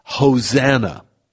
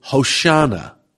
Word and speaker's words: Hosanna: Hosanna. Some pronounce it Hoshana.